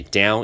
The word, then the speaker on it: dawn: 往下叫做 down，黎明叫做 dawn，OK，dawn、 okay? in 对不起，down in your body，the third part of 又来了，OK，一样 part 后面可以接到 of。